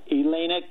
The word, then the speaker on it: Elena: and Eleni, if I'm pronouncing her name right, Elena Kagan.